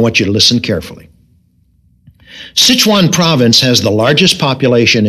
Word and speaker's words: Sichuan: You perhaps can help us here, Anita, with the pronunciation of S-I-C-H-U-A-N. – Sichuan. – That's the way I pronounced it, and I thought it sounded more like Sichuan, like the Chinese restaurant up the street, you know, Sichuan Village. Sichuan. S-I-C-H-U-A-N.